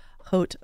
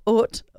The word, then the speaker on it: Haute: So if you're just tuning in, you are listening to a delightful discussion with Kathryn Marino, CEO of Haute OC. And Haute is spelled, I'm saying that wrong. Let me just take the H out and go Haute, Haute OC, H-A-U-T-E.